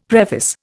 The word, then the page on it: preface: Indian